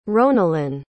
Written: ronelyn

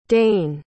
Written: daine

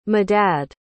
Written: medad

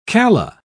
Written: calla

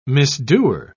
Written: misdoer

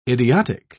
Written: idiotic

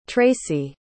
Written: tracee

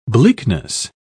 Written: bleakness

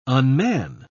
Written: unman

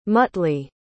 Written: muttley

